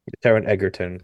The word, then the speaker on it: Taron Egerton: Is it Taron Egerton? What? How do you say his name? – Taron Egerton.